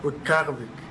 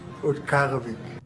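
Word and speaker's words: Utqiaġvik: And the new name for Barrow is Utqarevik. Utqarevik.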